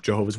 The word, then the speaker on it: Jehovah: Yeah, so the name Jehovah is very, very significant for the group, Jehovah's Witnesses, not just because it's in the name of the group, but because they think that it's God's proper name and it's properly pronounced Jehovah, and God ultimately will not respect you or respond to you if you don't call him by his name.